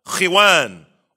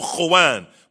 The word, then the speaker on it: khuwan: That mat that you put on the floor is called khiwan or khuwan. It's called Khiwan or Khuwan. More properly, Khuwan.